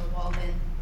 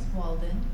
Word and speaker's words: Walden: Again, my name is Ashley Walden, and I'll spell that for you for the record. It's A-Y-E-S-H-U-A. Last name is Walden, W-A-L-D-E-N.